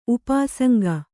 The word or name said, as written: ಉಪಾಸಂಗ